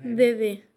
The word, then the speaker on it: Dede: Dede. – Dede. dede d D-E-D-E, Dede.